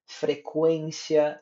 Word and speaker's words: frequência: Exemplo: aguentar ambiguidade, consequência, delinquente, equino, frequência, linguiça, tranquilo, frequentar. Vocês percebem que em todos os us foram pronunciados. Fracamente, mas foram.